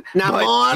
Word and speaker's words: Namor: Namor. – He's still white. – Namor. Say it right. – That's fucking bullshit. – Yeah. Say it right. – It's Namor, motherfucker.